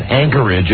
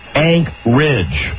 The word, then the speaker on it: Anchorage: And they said, no, it's not Anchorage, it's Anchorage. – Anchorage. – That's how locals pronounce it, Anchorage.